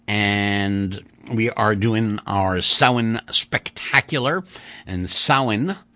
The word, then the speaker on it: Samhain: But I can tell you that it's spelled Samhain, but it's pronounced Samhain,